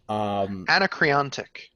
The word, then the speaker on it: Anacreontic: And supposedly the music is like, I don't even know how to pronounce it. – Anacreontic.